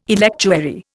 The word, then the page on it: electuary: Indian